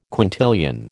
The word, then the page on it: quintillion: American English